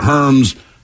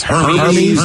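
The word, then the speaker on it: Hermès: You called it Herms. I said it's Hermes. – Hermes. – Okay. Hey, guys, just a heads up. Even though it's spelled Hermes, it's pronounced Hermes.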